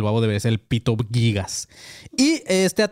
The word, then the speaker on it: Gigas: Pronto, eh, bueno, ¿cómo, se, ¿cómo se pronunciará? ¿Gigas o Gigas? como, ah, los según gigabytes. es Gigas.